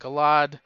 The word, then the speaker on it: Galad: It wasn't the best Eternal Warriors, because Galad, I think is a better way to pronounce it. Galad, was never like he was sort of a character in the story that just was doing things.